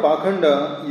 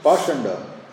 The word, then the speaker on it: Pakhanda: The word Pakhanda used in this verse is sometimes pronounced Pashanda.